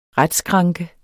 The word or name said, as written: retsskranke